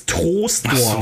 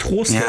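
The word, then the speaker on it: Troisdorf: Ähm, ich weiß nicht warum, ähm, aber man spricht es Trostdorf. – Ach so, ja. Ich weiß, es schreibt sich UI, aber warum auch immer spricht man es Trostdorf.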